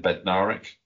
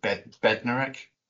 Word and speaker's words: Bednarek: The worst one is, and he's been playing for us for so long, and nobody can pronounce his name properly, is Jan Bednarek. Everyone calls him Bednarek.